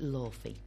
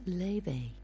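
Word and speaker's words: Laufey: I've been pronouncing it Lawfey because that's how you read it, you know, that's how it's spelt, Lawfey. But actually, it's an Icelandic name and it's pronounced Leve.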